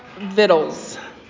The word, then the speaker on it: victuals: The other one that I just found out this morning was a word that I thought was victuals. V-I-C-T-U-A-L-S. That word is pronounced vittles.